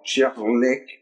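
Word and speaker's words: Kärlek: Euh, en fait, ce Karlek, c'est un mot suédois qui veut dire amour et qu'on ne sait absolument pas prononcer. En vrai, ça se dit genre Cherlek ou un truc comme ça.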